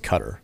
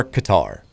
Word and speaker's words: Qatar: Now we've gotten into the pronunciation of, I was always told it was Cutter. – You're talking about guitar? – Yeah. Okay, well, here's the answer. It is generally acceptable for both it to be pronounced Cutter or Qatar.